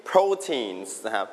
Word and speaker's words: protein: Meat, dairy, beans, eggs, all of these things, you can mainly find them in proteins. Proteins. Everybody repeat after me, proteins. One more time proteins คำนี้ออกเสียงว่า protein เน้นที่พยางค์แรกครับ